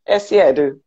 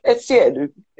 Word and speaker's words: Essiedu: ESSIEDU. You have to put the ESSIEDU in it.